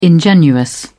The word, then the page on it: ingenuous: British English